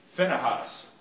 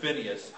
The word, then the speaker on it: Phinehas: His sons were Hophni and Phinehas, or some would pronounce that Phineas.